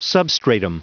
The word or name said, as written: substratum